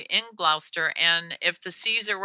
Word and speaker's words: Gloucester: When I first moved here, I pronounced it incorrectly. It's spelled like it sound. It should be like Gloucester, but they call it Gloucester, north of Boston.